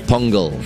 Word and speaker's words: Pongal: So it's important to pronounce it properly. – Pongal. – I feel like I'm going to hear this in my head all the time. Okay, it's Pongal.